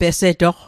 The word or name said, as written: pesetoq